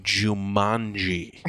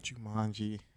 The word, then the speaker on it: Jumanji: No, Jumanji is how it's pronounced. – Jumanji.